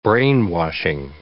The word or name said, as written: brainwashing